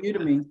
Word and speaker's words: Udemy: I think 17 years working with tech organizations like Oracle, LinkedIn, Facebook, and lately, is it Udemy? Is that how we Udemy.